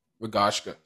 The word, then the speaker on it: Rogaška: Well, that's a perfect segue because I was going to say, after a season at Ammonia, you joined – I don't know if I'm pronouncing it right. Is it Rogaška? – Rogaška.